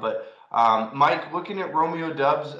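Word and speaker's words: Doubs: It's Romeo Dubs. – Romeo Dubs. – As Mike chimed in, Romeo Dubs there. – Romeo Dubs. Okay, so it is pronounced how I thought it was. The O is throwing me off there.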